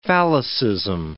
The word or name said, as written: phallicism